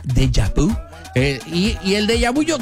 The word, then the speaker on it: déjà vu: Déjà vu.